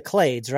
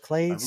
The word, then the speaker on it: Clades: It said it's pronounced the Clades, right? The Clades? – I believe so.